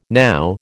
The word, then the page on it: now: American English